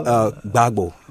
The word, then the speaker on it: Gbagbo: And he replaced Lauren, is it Gbagbo? – Yeah, Gbagbo.